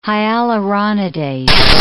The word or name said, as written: hyaluronidase